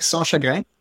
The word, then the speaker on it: Sanschagrin: So it's Nick Sanchagrin. It's French Canadian, and I appreciate it.